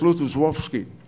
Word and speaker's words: Lutosławski: Well, I understand it's pronounced Lutosławski, which is, do you know how the name of the town, L-O-D-Z, is pronounced?